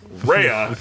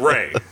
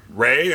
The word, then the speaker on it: Rhea: I pronounced her name Rhea because I knew a girl back in college spelt the same way, pronounced it right. Rhea. So I call up and I'm like, I'm looking to make an interview request for Rhea Seahorn. And immediately they're like, you mean Ray?